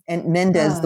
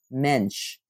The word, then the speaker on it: Mendes: And Mendes, the way I am saying it, I'm saying it the way it's spelled, but really it's pronounced Mensch, which is also interesting.